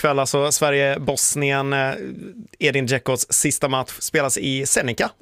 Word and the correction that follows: Zenica: vad det var? – Zenica, ja. – Zenica, ja, du får hjälpa mig med uttalet.